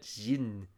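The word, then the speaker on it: Xin (欣): It's the actual second syllable in Liu Cixin's name, that Xin. Trying to read about how you're supposed to pronounce that. I read about it. It's the CH sound in the word cheap.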